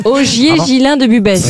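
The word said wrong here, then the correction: Busbecq: Ogier Gillin de Busbeck, pardon, j'ai écorché son nom.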